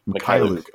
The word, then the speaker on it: Mykhailiuk: Mykhailiuk.